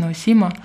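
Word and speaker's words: Наусима (直島): Вторая часть моего знакомства с Кусамой произошла, кстати, в Японии, на острове Наусима, по-моему, на русском это произносится Наусима.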